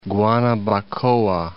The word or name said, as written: Guanabacoa